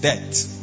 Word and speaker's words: debt: It's pronounced debt, but you know I'm talking about debt. D-E-P-T. D-E-B-T. Sorry, B-T. Debt.